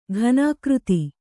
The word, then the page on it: ಘನೀಕೃತ: ghanīkřta